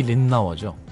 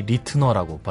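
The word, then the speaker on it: Ritenour: 대표적인 게 이제 리 린나워죠. 네. 그 전까지는 우린 다 리 리트너라고 발음을 했는데 아니다. 내 성을 린나워다. 뭐 린나워라고 발음해야죠.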